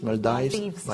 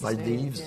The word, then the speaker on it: Maldives: How do you say in English, Maldives? – Maldives.